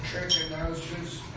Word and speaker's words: trichinosis: Could trichinosis, am I saying it right? – You're saying it right.